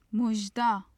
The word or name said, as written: muzhda